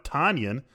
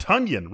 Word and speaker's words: Tonyan: It's not Robert Tonyan. It's Robert Tonyan, rhyming with Funyan.